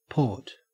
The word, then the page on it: port: Australian English